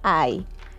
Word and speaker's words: eye: Hoy aprenderemos en la palabra I. misma pronunciación, pero en este caso puede ser escrita de dos maneras con dos distintos significados. El primero, I de ojo, y se escribe de la siguiente manera, pon atención. E, Y, E.